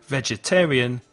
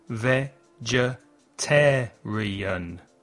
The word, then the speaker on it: vegetarian: Vegetarian tiene cinco sílabas. Escucha. Ve-ge-ter-ri-an. ¿Qué sílaba lleva el stress? ¿Qué se oye más fuerte? Vegetarian. Es la tercera. The third syllable. Listen and repeat. Vegetarian.